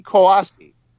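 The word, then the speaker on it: Kouassi: Or how we say that name? Tanguy Koyasi or something. – Yeah, we'll go with that. You're close enough. – Pronunciation. Koyasi. Yeah, I don't know why I was saying it weird.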